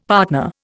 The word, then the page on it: partner: Indian